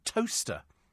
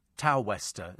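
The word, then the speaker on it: Towcester: I like the sound of that, Martin, in a place called Toaster. You haven't had anybody from Toaster. Is it Northamptonshire? It's spelt Tow-Wester, isn't it? Tow-Wester. But it's pronounced, I do know that, actually.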